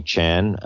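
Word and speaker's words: Tjan: So my Twitter feed is at Anthony Chan. My last name is pronounced C-H-A-N, but spelled T like Tom, J-A-N.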